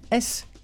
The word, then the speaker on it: Cremisi: Ça s'écrit comme ça se prononce, Cremisi.